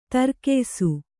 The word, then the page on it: ದೀರ್ಘಾಯುಸ್ಸು: dīrghāyussu; Kannada